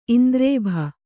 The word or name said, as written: ಇಂದ್ರೇಭ